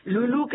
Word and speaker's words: Loulou: Vamos a escuchar a Lulú, que se escribe Loulou Gilberto.